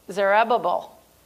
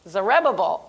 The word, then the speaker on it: Zerubbabel: God used Zechariah to encourage the people of God for the rebuilding of the second temple that was under the leadership of, help me say it correctly, Zerubbabel. Zerubbabel. Okay, we got it.